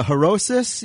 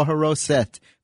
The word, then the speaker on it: haroset: Well, either harosis, you could pronounce it, or haroset. For some reason, in Hebrew, some words, as I was growing up, they taught me a lot of words that ended with an S sound. But for some reason, now they end in a T sound. It's the same thing, harosis or haroset.